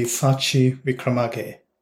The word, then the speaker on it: Sachi Wickramage: Yeah, the correct pronunciation would be Sachi Wickramage.